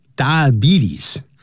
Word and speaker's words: diabetes: For today, we have this lesson 212, and you can see the title that has to do with some kind of disease known as diabetes. Diabetes, 这样发音，不要念 diabetes, B-T-S, 多发 E 的长音。但如果说他是个糖尿病患者，我们就要说 He is a diabetic，那是一个可数的名词。 There are diabetics， D-I-A-B-E-T-I-C，复数加个 S。好，我们现在就一起来看这里面文章的内容。 In the last decade， incidences of diabetes in Asia's youth has skyrocketed。